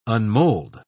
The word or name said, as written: unmold